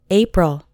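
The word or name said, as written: April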